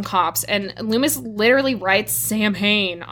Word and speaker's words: Samhain: This is Sam Hain, which, by the way, and I don't think Jacob knows this, but that's not how it's pronounced. – Yeah, no, you told me at one point. – It's Samhain? – Yeah. – It's a Gaelic word.